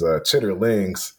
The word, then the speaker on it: chitterlings: So I guess the official pronunciation of it, well, you know, I guess the dictionary pronunciation, I would say, is chitterlings.